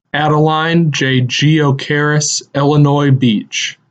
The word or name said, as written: Adeline Jay Geo-Karis Illinois Beach